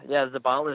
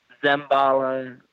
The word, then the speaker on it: Zabala: Yeah, Zabala is right. I've been called Zembala. Zabala. I mean, you name it. You can look at it 20 different ways.